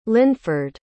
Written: linford

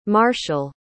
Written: marshall

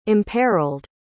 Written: imperiled

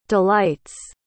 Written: delights

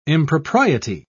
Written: impropriety

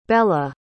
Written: bela

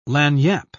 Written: lagniappe

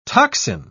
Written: toxin

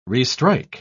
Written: restrike

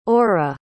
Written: orra

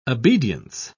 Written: obedience

